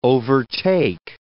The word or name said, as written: overtake